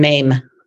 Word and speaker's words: Mame: That's fine. Mame. – Mame. Okay. – Yep. Like the musical.